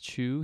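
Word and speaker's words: Chiu: I believe it's pronounced Chu.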